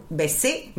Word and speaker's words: besé: Besé, mit der Betonung auf dem E, a una adia.